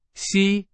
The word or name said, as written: 吸